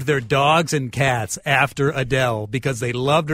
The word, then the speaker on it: Adele: Now somebody is pointing out, did she 5 years into superstardom, is she now calling herself Adele? – Adele is how we're pronouncing Adele.